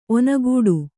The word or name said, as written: ಒನಗೂಡು